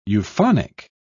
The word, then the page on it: euphonic: British English